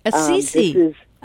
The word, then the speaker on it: Assisi: Assisi. – Assisi.